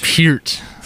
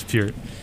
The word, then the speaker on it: Peart: Peart. – Is that really how you say it? – Yeah, it's Peart. Yeah, only Rush. True Rush fans know.